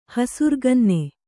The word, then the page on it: ಹಸುರುಗನ್ನಿ: hasuruganni